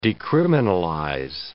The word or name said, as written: decriminalize